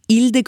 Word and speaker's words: Hilde: Jeg bodde i Italia jeg og het Hilde. Der uttaler de ikke H. Eh, så jeg må presentere meg da. Så jeg Ilde con lacca davanti, altså Hilde med hår framfor.